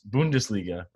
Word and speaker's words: Bundesliga: I tweeted this out today that first game back where, and not just like a game, I didn't mean to disrespect the Bundesliga, right? Is that how you say it? – Yeah, that is right.